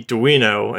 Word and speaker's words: Duino: I think it's funny that everyone can pronounce Arduino fine, but then they see Duino and they panic.